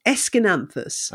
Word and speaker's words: Aeschynanthus: It is pronounced Aeschynanthus. – Okay. – It doesn't look like that, but it's a word, it's a letter salad that you're dealing with, but it's Aeschynanthus, member of the Gesneriad family.